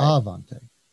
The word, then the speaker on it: Aivante: And for anybody listening, it's Aivante spelled with an I after the A, A I V A N T E dot com. So it looks like Ivante, but it's uh, it's pronounced right. Avante.